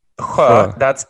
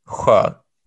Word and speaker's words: sjö: And sjö, that's lake. Sjö. – Okay. – It's spelled S-J and then A, which is an O with two dots over it. Sjö. So the S-J... The SJ is pronounced or and then the O is an so yeah, you got it.